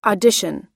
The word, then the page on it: audition: American English